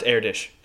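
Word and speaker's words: Erdős: It's pronounced AirDish, and also yes. – Well, you know what? I was fucking close enough. – No, you know what? I should give you plenty of credit, Tyler, because it is spelled AirDOS, but dude's super foreign. So it's pronounced...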